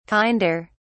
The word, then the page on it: kinder: British English